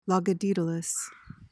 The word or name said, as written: logodaedalus